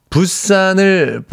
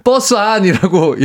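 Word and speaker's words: Busan: 부산을 버스 안, 아, 부산을 부산. 버스 안이라고 읽고 자존심이 상했었어요.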